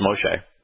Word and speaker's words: Moshe: You know, I saw bits and pieces of Kings and Gods, the you know, most recent Moses movie, and they did say his name right, it's Moshe, but they never did bother to explain what it means.